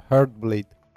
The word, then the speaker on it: Heartbleed: Эксперты в один голос твердят, что это подобная эпидемия в мире безопасности, а именно это уязвимость библиотеки OpenSSL, которую назвали Heartbleed. Я правильно сказала? – Ну да, Heartbleed.